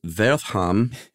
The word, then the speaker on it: Wertham: And that Wertham or Wertham, as you, I think, probably correctly pronounced.